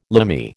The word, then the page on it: Limey: American English